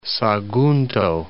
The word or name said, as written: Sagunto